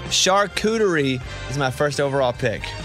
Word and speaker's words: charcuterie: Is that how you say it? Charcuterie? – Yeah, charcuterie.